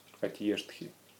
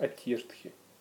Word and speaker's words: Атьештхи: Атьештхи. Ну, можете мягкие. Обычно мягкие. Мягко произносится. Атьештхи.